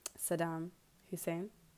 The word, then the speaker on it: Saddam Hussein: like he doesn't honor Saddam Hussein.